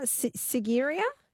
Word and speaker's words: Sigiriya: Sigiriya? – Yes.